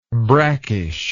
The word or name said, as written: brackishness